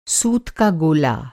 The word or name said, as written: गोला